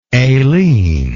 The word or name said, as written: alene